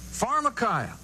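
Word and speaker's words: Pharmakeia (φαρμακεία): The E-I is pronounced as an I. Pharmakeia.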